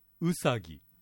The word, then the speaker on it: うさぎ: まず今年の主役のウサギね、新潟ですと頭のうにアクセントを置いてうさぎと発音しますね。